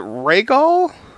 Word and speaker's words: Rhaegal: is it Rhaegal? – yes. Okay. Yes, you're pronouncing it correctly.